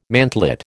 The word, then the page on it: mantlet: American English